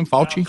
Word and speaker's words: Fauci: Yeah, and looking at some of the things, Dr. Fauci, is that how you say his name, Fauci? – Close enough.